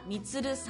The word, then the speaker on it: 満: はい、で今週ご紹介するのは砂川でおむすびみつるというお店を営む船田みつるさんという方なんです。 – あ、みつるさん、あの満足の満という字を書きますが、それでみつると読むんですね。